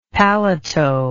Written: paletot

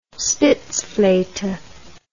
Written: spitzflote

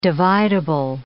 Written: dividable

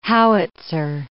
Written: howitzer

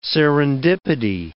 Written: serendipity

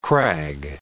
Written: crag

